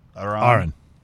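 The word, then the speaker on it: Arran: Aran. – Aran. Gotcha. I keep Aran. Putting emphasis on rhymes the wrong with, syllable. – Rhymes, R rhymes with car.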